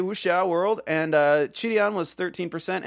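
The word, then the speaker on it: Qidian: Apologies again for mispronouncing Chidian the entire last episode. It's Chidian.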